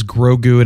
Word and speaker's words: Grogu: Yeah, and I heard Brogu as in Brogu. – Yeah, I looked it up too just to see if anyone else was, because I didn't know how to spell it, and it is Grogu, and everybody's spelling it G-R-O-G-U is what it is.